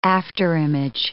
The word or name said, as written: afterimage